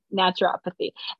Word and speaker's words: naturopathy: It's a little, yeah, it's like nature and then pathy put together, naturopathy.